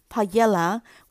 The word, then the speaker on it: paella: 那对于会考生来讲，就以出版社所提供的音档发音 paella 为主。